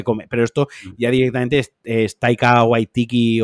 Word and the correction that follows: Waititi: Taika, eh, Waititi.